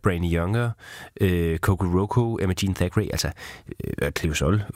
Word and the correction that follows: Cleo Sol: for den sags skyld, eller Cleo Sol, som det er udtalt,